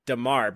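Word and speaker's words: D'Marr: I hate his name because it's D apostrophe M-A-R-R, and you say it like Damar, but it's hard to...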